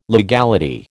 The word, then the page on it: legality: American English